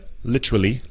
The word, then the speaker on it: literally: Literally, en el Reino Unido sería literally, literally. Aquí pronunciamos la T como si fuera una D muchas veces.